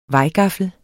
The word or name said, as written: vejgaffel